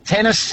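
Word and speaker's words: Tennys: And a special shout out to, and this is his real name, former professional tennis player Tennis Sangren. His first name's Tennis.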